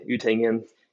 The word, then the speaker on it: Utengen: So, yeah, my name is Auden Utengen.